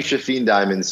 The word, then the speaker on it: Shafin Diamond: So and his name is spelled Shafin, S-H-A-F-I-N, and then the beautiful last name, Diamond. – So my first name is actually Shafin Diamond.